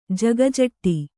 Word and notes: ಜಗಜೆಟ್ಟಿ: Kannada

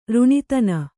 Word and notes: ರುಣಿತನ: ruṇitana

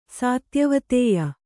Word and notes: ಸಾತ್ಯವತೇಯ: kannada

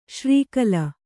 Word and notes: ಶ್ರೀಕಲ: śrī kala

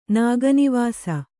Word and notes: ನಾಗನಿವಾಸ: kannada

